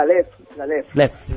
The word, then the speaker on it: Leffe: la famosa Leffe, no sé si se pronuncia así. – La Leffe.